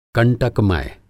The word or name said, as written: कंटकमय